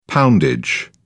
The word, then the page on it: poundage: British English